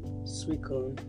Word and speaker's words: Suicune: So now I have a Pikachu pin and a Suicune, that's how you pronounce it.